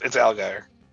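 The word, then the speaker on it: Allgeier: Is that how you say his name, Allgaier? Because I call him Allgaier all the time. – It's Allgaier.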